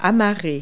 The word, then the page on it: dock: verb